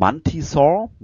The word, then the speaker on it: Mantisaur: Okay, Platz Nummer 9 ähm, mit 2,88 Prozent ähm, aller abgegebenen Stimmen ist Mantisaur, Mantisaur, wie spricht man das eigentlich aus? Mantisaur. – Mantisau. Wenn man dem Englischen nachgeht. Nach den deutschen Hörspielen wäre es Mantisau.